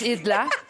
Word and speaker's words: Edler: Pacho, estás frente a Julia Edler, se escribe Edler, pero es Idla.